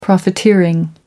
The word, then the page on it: profiteering: American English